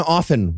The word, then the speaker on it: often: Also, anyone who pronounces the T in often while we're on the subject.